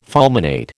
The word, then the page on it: fulminate: American English